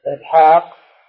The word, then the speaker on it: إسحاق: إسحاق فيها ألف نطقا لا كتابة.